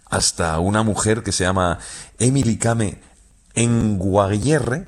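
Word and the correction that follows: Kngwarreye: lo he dicho mal. – Emily Kame. – Con Guarreye.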